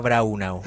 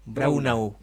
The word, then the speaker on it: Braunau: Nosotros los que tenemos raíces germanas le decimos Nueva Braunau. – Ya, perfecto. Nueva Brauna o Nueva Braun, ¿cómo es? – Braunau.